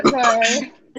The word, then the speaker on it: adobo: Wait, adobo. How's it pronounced? Adobo. Adobo. Adobo. Adobo. Yeah. All right, cool.